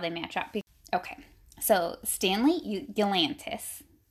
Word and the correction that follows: Yelnats: I don't know how to say his last name. – Yelnats. – Yelnats. Oh, that makes sense.